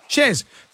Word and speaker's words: Chers: Shers or Shers? – Shers. – Because girls here have written your name as C-H-E-R-S. Is that how you spell it? – That's how you spell it.